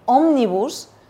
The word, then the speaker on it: Omnibus: y sobre todo por acompañarnos con el tema que vienes a hablarnos hoy, que es de la ley Omnibus, que ya me has confirmado que lo he pronunciado bien.